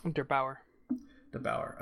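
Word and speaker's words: der8auer: Der Bauer.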